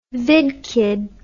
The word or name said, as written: vidkid